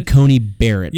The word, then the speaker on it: Coney Barrett: Coney Barrett. – Yeah, I believe that's how it's pronounced.